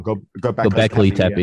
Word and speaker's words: Göbekli Tepe: And you start looking at things like the pyramids and Göbekli Tepe, and I always say it wrong. Göbekli Tepe.